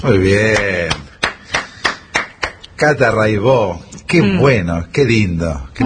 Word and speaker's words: Raibaut: Se escribe Raibaut, métanse, búsquenla, Rai con Y, Baut con B larga, Catalina Raibaut, Raibó se dice, y bueno, Cotidiano son 10 canciones, hay un documentalito muy lindo.